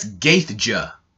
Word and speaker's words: Gaethje: It's spelt G-A-E-T-H-J-E. That's Gaethje, but it's pronounced Gaethje.